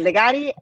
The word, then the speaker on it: Callegari: Yes, Callegari and dot wine.